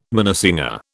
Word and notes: minnesinger: British English

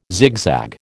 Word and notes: zigzag: American English

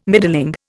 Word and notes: middling: Indian

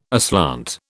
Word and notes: aslant: British English

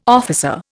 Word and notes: officer: Indian

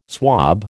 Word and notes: swob: American English

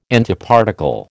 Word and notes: Antiparticle: American English

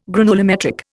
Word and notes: granulometric: Indian

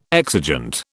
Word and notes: exigent: British English

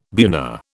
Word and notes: buna: British English

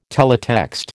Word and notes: teletext: American English

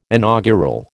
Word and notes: inaugural: American English

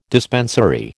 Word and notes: dispensary: American English

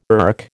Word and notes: erk: American English

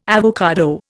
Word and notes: avocado: Indian

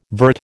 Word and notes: vert: American English

